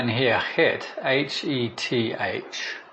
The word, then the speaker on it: Heth: In the Hebrew, it's sort of pronounced like het, because you have the he here.